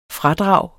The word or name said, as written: fradrag